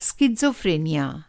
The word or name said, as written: सिज़ोफ्रेनिया